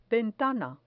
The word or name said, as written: ventana